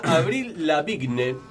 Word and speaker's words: Avril Lavigne: Nace la cantante norteamericana Avril Lavigne.